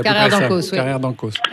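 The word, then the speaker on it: Carrère d'Encausse: Donc voilà, et suivi en plus d'un débat derrière qui était hyper passionnant, animé par Marina Carrère d'Ancos, si mes souvenirs sont bons, si je n'écorche pas son nom. – Carrère d'Ancos. – C'est ça, exactement.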